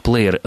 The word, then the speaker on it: плеер: А почему плеер произносится как плеер, понятно, что мы идем вслед за произношением, а на письме история несколько иная.